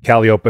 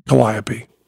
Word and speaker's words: Calliope: Everybody says Calliope. I believe that's the proper pronunciation. But I love the fact, telling Stephen Fry, that Calliope was correct. But once he started talking carnival, motherfucker, you go to Calliope.